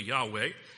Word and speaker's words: Yahweh: Jehovah, sometimes used with the letters YHWH, pronounced in English occasionally Yahweh,